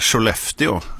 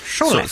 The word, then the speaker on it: Sollefteå: Har du hört den här nyheten att man tenderar så här Sollefteå, alltså även på rikssvenska? – Sollefteå. – Så är det på något sätt som att man har lagt sig till med det lokala uttalet. – Säger man verkligen Sollefteå? – Jag får säga vad man vill.